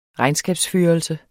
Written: regnskabsførelse

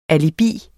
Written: alibis

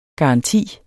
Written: garanti

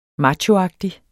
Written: machoagtig

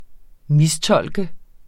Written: MISTOLKENDE

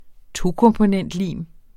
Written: tokomponentlim

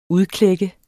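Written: UDKLÆK

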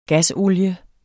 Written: GASOLIEN

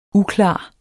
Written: uklar

